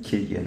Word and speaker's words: Cillian: Killian oder Cillian Murphy, wie auch immer. Wie spricht man ihn aus? Killian? Killian. Killian Murphy. Killian.